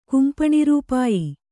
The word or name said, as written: ಕುಂಪಣಿರೂಪಾಯಿ